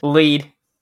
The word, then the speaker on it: Lwyd: Lwyd. Mary Lwyd. There's no right way to pronounce it, it's Welsh, there's way too many consonants.